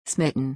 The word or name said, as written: smitten